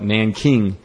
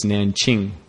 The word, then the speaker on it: Nanjing: Uh, so I guess if you were an American, you'd call it Nanking. But uh, I think the proper pronunciation is Nanqing, Qing.